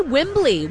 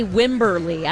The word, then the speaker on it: Wembley: I don't know why I can't say Wembley. Wembley. I keep wanting to say Wimberley. I don't know why. Wembley.